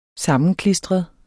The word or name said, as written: sammenklistret